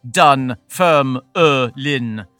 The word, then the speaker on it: Dunfermline: That has four syllables when you say it. Dunfermline.